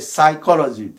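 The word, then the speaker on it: psychology: だから、本、プッシュで始まるんですけども、英語ではその P を発音しないで、サイコロジーって言ってます。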